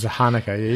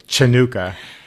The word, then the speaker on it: Hanukkah: I like to spell it in Spanish because J is a ch, and if you do ch, people say Chanuka, And if you don't do ch and you put an h, then you just think it's a plain old h.